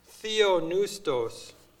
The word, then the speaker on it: theopneustos: But word number 2315 is theopneustos, but it's spelled T-H-E-O-P-N-E-U, so the P is silent. So if you recognize the base words, the P-N-E-U is air, right?